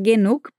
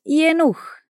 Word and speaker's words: genug: No Hochdeutsch, a pronúncia do G na palavra genug, por exemplo, tem um som de K, porém com um soprinho no final. Genug. Genug. No Berlinerisch se diz Jenuch.